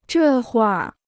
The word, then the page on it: 这会儿: zhè huìr